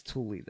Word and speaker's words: Thule: I've heard people pronounce that so many different ways. Or Thule, Thule. – I'm going to say Thule. – I think it's Thule, though, because I listened to a documentary today, and I think it's Thule.